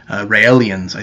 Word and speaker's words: Raelians: There were even UFO religions like the Raelians, I think it's how it's pronounced, R-A-E-L-I-A-N-S,